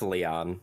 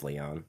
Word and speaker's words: Leon: It's pronounced Leon. Yeah. I used to call him Lion as a kid, but it's Leon.